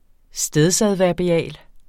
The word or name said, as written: stedsadverbial